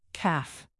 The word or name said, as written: calf